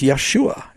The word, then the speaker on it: Yeshua: In Hebrew, it's Yeshua. And I agree with that. That is the right pronunciation in Hebrew, or close as we know.